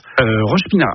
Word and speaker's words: Roche-Pinard: Alors, Roche-Pinard, c'est ça, c'est bien comme ça qu'on prononce. – Roche-Pinard.